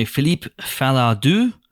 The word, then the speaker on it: Philippe Falardeau: This is directed by Philippe Falardeau. Spot on French pronunciation there.